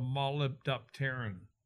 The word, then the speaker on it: molybdopterin: it forms a thing called molybdopterin. Molybdopterin. I can say that really well, but that's how you say it.